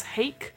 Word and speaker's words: Hake: And interestingly enough, and I'm sure you knew this, so it's written obviously by Matthew Mercer, but with also another person called James Hake, rhymes with cake.